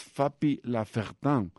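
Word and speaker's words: Fapy Lafertin: Se llama Fapi Lafertin, perdón, es una vez más Fapi Lafertin, o se escribe Fapi Lafertin.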